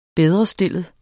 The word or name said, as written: bedrestillet